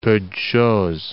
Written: peu de chose

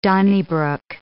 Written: donnybrook

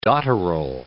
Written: dotterel

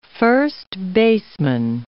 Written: first baseman

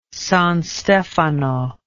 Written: San Stefano

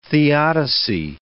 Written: theodicy

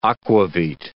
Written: akvavit

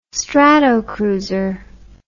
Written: Stratocruiser

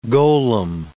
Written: golem

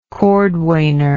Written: cordwainer

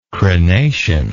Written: crenation

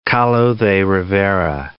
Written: Kahlo de Rivera

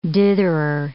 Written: ditherer

